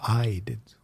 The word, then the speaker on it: Ide: Well, I just got a book out of the library called Righteous by Joe, I think his name is pronounced Ide. Maybe it's I-D. It's I-D-E.